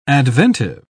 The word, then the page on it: adventive: British English